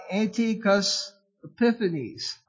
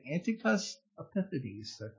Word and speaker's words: Antiochus Epiphanes: You had a period there where there was an invasion given by Antiochus Epiphanes. I said it right. Now you try to say it. Antiochus Epiphanes.